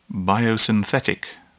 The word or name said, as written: biosynthetic